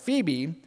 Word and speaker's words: Phoebe: And most scholars will tell you that this first name mentioned in verse 1, Phoebe, I know it doesn't look like that's how it's pronounced, but Phoebe, they believe that she was the one who actually delivered Paul's letter from Corinth to the saints in Rome.